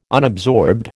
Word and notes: unabsorbed: American English

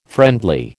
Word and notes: friendly: American English

